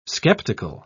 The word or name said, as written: skeptical